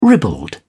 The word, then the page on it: ribald: British English